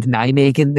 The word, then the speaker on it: Nijmegen: I would just like to congratulate you on your correct pronunciation of both Valwijk and Nijmegen there, because I have been pulled up on this podcast before for mispronouncing Nijmegen.